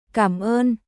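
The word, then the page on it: Cảm ơn: カムオン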